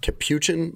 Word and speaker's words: capuchin: Because they recovered a, it's pronounced capuchin monkey.